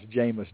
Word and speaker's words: Jameis: you would pronounce Jameis Jameis. – Because that's the way his grandma says it.